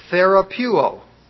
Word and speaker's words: therapeuo: Therapeuo. It sounds just like therapy.